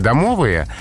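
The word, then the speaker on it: домовый: Пока словари фиксируют по старинке домовые, но совершенно не будет удивительно, если эта фиксация изменится.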